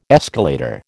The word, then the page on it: escalator: American English